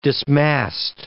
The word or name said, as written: dismast